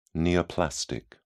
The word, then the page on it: neoplastic: British English